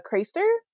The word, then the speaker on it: Craster: So I'm gonna lean into the British pronunciation because I think that's how it sounds to me. – Okay, go ahead. – Craster.